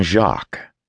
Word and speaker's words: Jacques: had named his son Jacques. The French pronunciation sounded almost like shock,